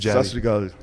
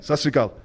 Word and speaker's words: Sat Sri Akal: Sat Sri Akal. – Say again? – Sat Sri Akal. – Sat Sri Akal.